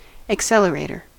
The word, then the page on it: accelerator: American English